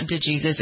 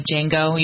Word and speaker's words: Django: Well, it's like, it's not DeJesus, it's like Django. You know how there was a takeoff on, Yeah. So the D was not pronounced.